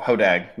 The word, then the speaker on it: Hodag: as the Happy Ho Dog mentioned, yes, uh, we do. Uh, we do absolutely goof off. Hodag, not Dog. I said that wrong.